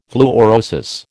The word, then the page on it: fluorosis: American English